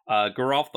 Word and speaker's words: Garof: Garof. He's the one I always forget to also say the hard G on. Garof the Fleshrite, Ariette the Beguiler, Satoru the Infiltrator, Karvek the Punisher, Annie Flash the Veteran, and Kellen the Kid.